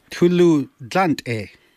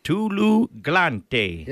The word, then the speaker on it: Tuluglante: How do you say, how's the road? – Tuluglante. – Tuluglante. – Yeah, that's perfect. Tuluglante.